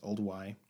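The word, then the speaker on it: Olduvai: I think it's pronounced Old Y Gorge.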